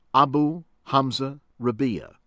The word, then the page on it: Abu Hamza Rabia: American English